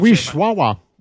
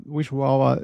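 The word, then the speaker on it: Wisława: Wishwawa. – Är det så? – Ja, för det där L-et ska uttalas som Wishwawa, Swinborska och sånt där.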